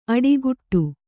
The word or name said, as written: ಅಡಿಗುಟ್ಟು